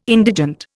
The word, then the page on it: indigent: Indian